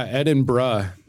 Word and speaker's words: Edinburgh: Yeah, Edinburgh. – Edinburgh? – Yeah, like bruh, like when people say, like, what are you doing, bruh? It's like that. It makes no sense phonetically.